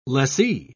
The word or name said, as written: lessee